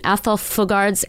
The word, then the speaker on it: Athol Fugard: In Montreal, where at 19 years old, he won accolades for his performance in Athol Fugard's, am I saying that right? – You are.